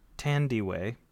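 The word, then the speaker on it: Thandiwe: I see the pronunciation. It's Tandy Way.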